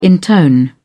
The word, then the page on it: intone: British English